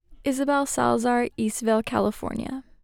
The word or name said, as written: Isabelle Salazar